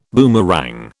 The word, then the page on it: boomerang: British English